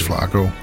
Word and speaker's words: Flaco: Flaco Jiménez. – Flaco, ja. Zeg ik Franco? Ja, ik zeg ja, Franco, Flaco. Maar het is Flaco. Heel wat anders. Heel Jan anders.